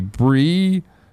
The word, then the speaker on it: Brigitte: Yesterday, the pronunciation of Brigitte in Overwatch is roughly Brigitta. This is a Swedish character. – I said Bridget.